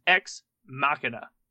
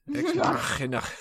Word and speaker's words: Ex Machina: And the first movie on our slate is Ex Machina. Did I say it right? Yep. – Yeah. Ex Machina.